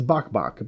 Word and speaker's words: Bok Bok: And that's where the Bok Bok comes from. Even though it's spelled B-O-K-B-O-K. – Naturally. – Yeah, it's Bok Bok.